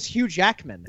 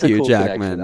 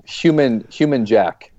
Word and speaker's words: Hugh Jackman: My 11-year-old son keeps pronouncing his name. – Hug. – Human Jack is what his name is. – Human Jack. – Stage name. – Jew Jackman or something. Yeah, he can't get it right.